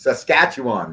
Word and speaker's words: Saskatchewan: And Calgary are playing Saskatchewan. I can't even pronounce it. Saskatchewan, man.